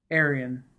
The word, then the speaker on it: Arian: Okay, and just to make sure that I don't say it wrong, Arian.